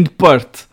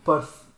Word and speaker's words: Perth: Nasci a 1 de julho em mil e, uh, mi, uh, 1980 em Perth, Western Austral.